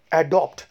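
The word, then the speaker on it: adapt: एडॉप्ट अब next word है दोस्तों एडाप्ट तो ज़रा ध्यान से सुनिए क्योंकि एडॉप्ट और एडाप्ट में कहीं आप कंफ्यूज ना हो जाए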